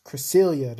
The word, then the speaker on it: Cresselia: It's Cresselia, that's how it's pronounced.